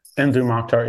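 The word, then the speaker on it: Mdou Moctar: And then, you know, and I want to talk about your partnership with, that you did with, on Nakama with, Modu Mokhtar, I think I'm pronouncing his name right. How do you say his first name? – Mdu Mokhtar. Yeah.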